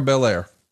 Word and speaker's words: Belair: I mean, you know, Bianca Blair, is that her last name? – Yeah, Bianca Belair.